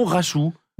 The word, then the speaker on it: Rachou: Rachou, ça s'écrit comme un cachou mais avec un R. R-A-C-H-O-U, tout simplement.